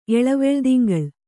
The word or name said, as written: ಎಳವೆಳ್ದಿಂಗಳ್